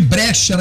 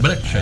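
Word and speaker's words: Brescia: Brecha. É... – Acho que a pronúncia é essa, Brecha. Breccia.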